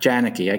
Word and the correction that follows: Janicki: I keep mispronouncing, right? It's Yanishay. Am I? Is that? – How it's Yanishay. Yes, Yanishay. Yeah.